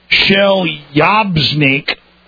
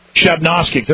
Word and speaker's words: Chelyabinsk: Russia, which has been striving to stop the spread of a deadly bird flu across the territory, said an outbreak in Chelyabinsk. I'm sorry, I just can't pronounce it. Chelyabinsk. Chelyabinsk. There we go.